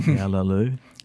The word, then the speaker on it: Cağaloğlu: McClatchy, in the August 15th, 2011 issue of The New Yorker, we published your poem, CaĞaloĞlu, that's CaĞaloĞlu, pronounced, roughly speaking, CaĞaloĞlu.